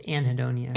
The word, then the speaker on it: anhedonia: It's like anhedonia or something. Mm-hmm. Lack of caring about things. – It's anhedonia. – Anhedonia. Yeah. Thank you. I've never known how to pronounce that right.